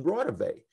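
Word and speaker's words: Raudive: And so after they left, I immediately Googled it, and I realized, of course, he was mispronouncing it. It's Constantine Rawdive, the electronic voice phenomena pioneer who died back in the 70s.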